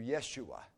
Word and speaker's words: Yeshua: Jesus is the Greek for the Hebrew Joshua, and it's pronounced in Hebrew Yeshua.